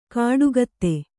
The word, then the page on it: ಕಾಡುಗತ್ತೆ: kāḍu gatte